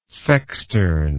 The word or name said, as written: sextern